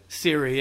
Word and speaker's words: Siri: So I didn't have Siri. Is that how you say it? – Siri, yeah.